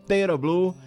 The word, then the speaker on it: Tera Blue: Eu agora falei a pronúncia correta, né? Eu comecei com um Tera, botando um acento onde não tinha. Então, Tera Blue, 40 anos de carreira, experiência para dar e vender...